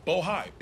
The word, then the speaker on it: Bohai: Rosemont Seneca Bohai. I want to make sure I pronounce it right. Bohai, B-O-H-A-I.